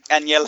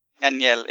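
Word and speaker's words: Ángel: It's actually, you know, he's Mexican, so it's pronounced like that. – I really like the idea of, like, one person be like, no, they're totally legit and cool. I was like, no, seriously, they're spooky. – Aniel? – Aniel. It's spelled angel, but And okay. People can say Angel too, and he'll not say no to it, but...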